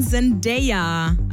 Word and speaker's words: Zendaya: Zendaya? – Zendaya. Nein, Zendaya. Also so wird es Englisch ausgesprochen. Zendaya.